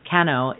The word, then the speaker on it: Kano: And so her interpretation is, when it seems darkest, Kano, that's how you say it, Kano is sitting on the road before you.